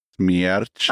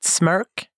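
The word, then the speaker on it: Smärch: Schmierch. Ja, ah, typ. Alltså det räcker. Folk har sagt smörk. Och det, det känns alltid lika roligt.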